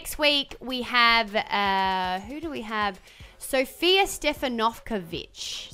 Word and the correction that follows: Stefanovic: Is that how I say her name? – Stefanovic. – Oh, there you go. – Because it's the emphasis that wasn't like Carl Stefanovic's last name, because that's been Aussieified.